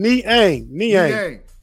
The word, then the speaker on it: Niang: How do you say his last name? – Look like it sound. Niang.